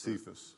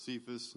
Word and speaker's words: Cephas: And when James, I can't pronounce Cephas. – That. Cephas, mm-hmm.